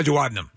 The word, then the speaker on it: duodenum: A duodenum.